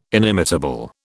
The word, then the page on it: inimitable: British English